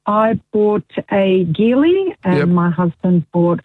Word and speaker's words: Geely: It is a hard G, not a soft G, but most people say Geely.